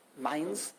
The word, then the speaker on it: Mainz: Germany at that time was the center of Yadus, in Mainz, and Magencia, which is called, we call it in English, it's pronounced Mainz.